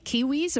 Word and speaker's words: Kiwiz: Tell us a little bit about it's spelled K I W I Z. Am I pronouncing it right? Kiwiz and what you offer? Yes, that's right.